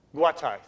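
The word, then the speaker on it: Guatay: Over here, just recently, I always mispronounce it, Guate. How's it pronounced? What? Guatai, thank you.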